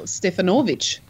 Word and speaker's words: Stefanovic: First name, K-A-R-L. Middle name. – Yes. – Middle name, S-V-I-L-E-N-K-O. Last name, S-T-E-F-A-N-O-V-I-C. How would you say that name? – Yeah, well, you'd say, I know who you're talking about. It's the great Karl Stefanovic. Stefanovic would be the correct pronunciation.